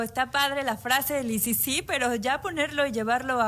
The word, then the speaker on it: Isisi: Ajá, se escribe ISISI. – Sí, un juego de palabras para que justo suene como ICC.